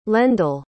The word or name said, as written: lendl